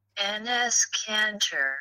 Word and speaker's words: Enes Kanter: Enes Kanter.